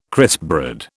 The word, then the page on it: crispbread: British English